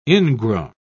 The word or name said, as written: ingrown